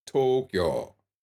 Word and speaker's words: Tokyo: A lot of people will say, for example, they'll pronounce the Japanese capital with three syllables. It only has two. Tokyo. And it's a long, the to is long. Tokyo. The kyo is shorter than the o in to, Tokyo.